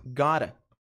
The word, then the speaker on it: got to: Quando reduzido a pronúncia, soa como gotta.